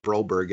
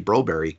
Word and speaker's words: Broberg: and only and, and uh, many Russian players, but with the Swedish players, um, you know, in Sweden, that Berg is, you know, Wennberg, Broberg. It's it's Wenberry, Broberry. From listening are listening to uh, Swedish hockey games, that's how all the announcers pronounce all these players.